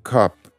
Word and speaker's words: cup: Η κούπα είναι με U, οπότε το στόμα είναι σχετικά κλειστό, είναι cup, cup.